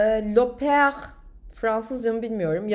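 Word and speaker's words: L'Aupaire: L e, yukarıdan virgül A U P A I R E diye yazılıyor arkadaşlar. Loper diye okunuyor Fransızca.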